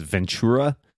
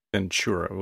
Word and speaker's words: Ventura: I think the correct, quote unquote, correct way to say it is Ventura. – Ventura with a C. – Not with a CH.